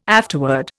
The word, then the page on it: afterword: Indian